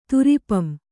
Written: ತುರಿಪಂ

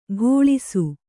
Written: ಘೋಳಿಸು

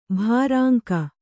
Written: ಭೃಂಗಿ

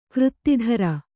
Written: ಕೃತ್ತಿಧರ

